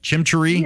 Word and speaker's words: chimichurri: And I know Dan's listening, though, and hopefully he's not writing while he's driving, because we're going to make a chimichurri sauce this weekend? Is that how you say it? Chimichurri? – Yeah, chimichurri sauce.